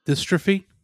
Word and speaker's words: dystrophy: He suffered from type 2 melatonic muscle dystopia, I believe it's pronounced. – Dystrophy.